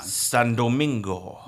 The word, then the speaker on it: San Domingo: Wait, you gotta say it like Ricardo Montalban. – San Domingo. – There you go. – Is that better? – Yeah, it's better.